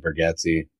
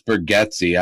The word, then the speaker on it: Bargatze: And I'm saying his name several times because I found out that I was saying it wrong for like 5 years. So how do you say it? I was saying it wrong. What? – It's Bargatze. I've been saying Bargatze.